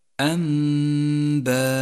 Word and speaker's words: أنباء: لاحظ كيف لون حرف الواو من نفس الكلمة باللون الرمادي وكذلك الألف، لونها مصحف التجويد باللون الرمادي إشارة إلى عدم نطقها لأن هذا الرسم خاص بالقرآن الكريم، ولكن هذه الحروف لا تلفظ، تقرأ الكلمة هكذا أنباء.